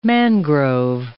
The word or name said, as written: mangrove